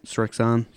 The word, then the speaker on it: Srixon: Srixon.